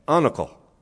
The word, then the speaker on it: anacal: And there was the Irish word, A-N-A-C-A-L, pronounced, you ready? Unical.